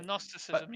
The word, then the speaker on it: Gnosticism: You don't have to pronounce the G. Like, oh, Gnosticism.